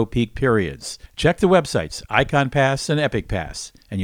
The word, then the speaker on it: Ikon: Then there's the Icon, that's spelled I-K-O-N, pass, the Icon Pass.